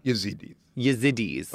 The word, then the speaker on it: Yazidis: Yazidis.